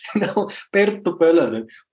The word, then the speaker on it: Perttu Polonen: My name is, well, I cannot even say it in English, Perttu Polonen. But how you spell it is Perttu Polonen.